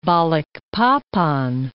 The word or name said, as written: Balikpapan